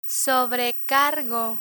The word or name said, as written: sobrecargo